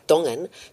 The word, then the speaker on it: Tongan: Yeah, it's a it's a, a, Tongan a group of Tongan folk, and I should actually be pronouncing it properly, Dongan Tongan folk that um,